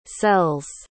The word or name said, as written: sells